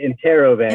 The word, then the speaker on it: Interrobang: So it's Interrobang? – We pronounce it Interrobang.